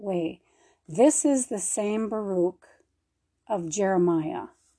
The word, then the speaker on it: Baruch: Let's look at his Oh, the poor guy's name is Baruch. There you go. We're saying it right.